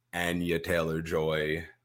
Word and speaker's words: Anya Taylor-Joy: We got our family in court, Anya Taylor-Joy.